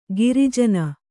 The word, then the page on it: ಗಿರಿಜನ: giri jana